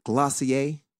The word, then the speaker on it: Glossier: Are you familiar with Glossier? Is that one of your... – No. – Did I say it right? – You said it right.